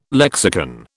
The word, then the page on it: lexicon: British English